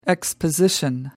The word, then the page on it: exposition: American English